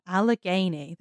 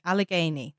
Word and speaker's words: Allegheny: Allegheny. – There you go. Allegheny.